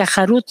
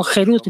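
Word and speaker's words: Jerut (חֵרוּת): Es como que está escrito, Harut, tallado en las tablas, no se pronuncia Harut, sino Jerut, libertad, pues ha llegado la libertad del ángel de la muerte.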